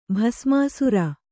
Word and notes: ಭಸ್ಮಾಸುರ: bhasmāsura

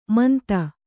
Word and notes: ಮಂತ: manta

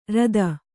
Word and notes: ಋತಿ: Kannada

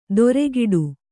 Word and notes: ದೊರೆಗಿಡು: doregi'ḍu

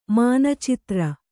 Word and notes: ಮಾನಚಿತ್ರ: māna citra

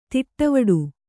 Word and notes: ತಟವಟ: taṭavaṭa

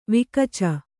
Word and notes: ವಿಕಚ: vikaca

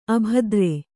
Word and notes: ಅಭದ್ರ: abhadra

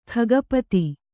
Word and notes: ಖಗಪತಿ: khagapati

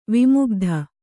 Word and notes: ವಿಮುಗ್ಧ: vimugdha

